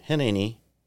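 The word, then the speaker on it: Hineni (הנני): Hineni, that's how you say it in Hebrew, here I am.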